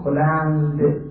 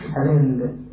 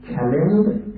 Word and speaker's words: کلند: کلمه کلنگ ما میگیم هاست، این که ما میگیم رو زمین میزنید میکوبید زمین رو میکنید، کلنگ نیست در پاسی، این کلنگ که در اصل تلفظ صحیح فارسی اون کلند، کلند مثل یه دری بزنی بعد میشه کلند با دال آخرشه،